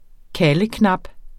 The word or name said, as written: kaldeknap